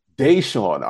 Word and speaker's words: Deshaun: Deshaun.